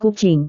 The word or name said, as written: kucing